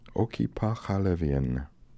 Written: okipakhalevian

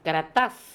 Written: Karatas

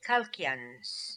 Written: Kalkians

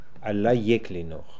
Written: Alla Ek Linokh

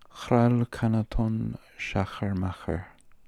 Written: khralkanatonschacherma

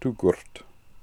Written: Toogoort

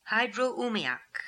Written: Hydro Oomiak

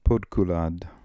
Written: Podkoolad

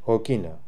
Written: Okina

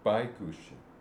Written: Baigooshi

